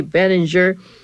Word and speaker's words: Benninger: He was of Germanic origin, another German name referred with a hard G, Benninger.